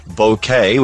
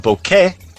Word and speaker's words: bokeh: By the way, I'm told from a Japanese friend at Tamron that the word bokeh, which that's how we've pronounced it here, as in a floral bokeh, B-O-K-E-H, a Japanese word, is not pronounced as in the Canadian A, right, which is how the Canadians say it. Hello, Walt, and all my Canadian friends. Canadian A. But rather a soft E, as in the sound of the E in the word bet.